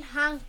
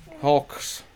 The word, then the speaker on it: Hulk: And then I got Spider-Man, Captain America, and Hunk toy. – And then? – You got Hunk? You don't even say it right. – You got like two or three Hunks. – Yeah. – Hulks.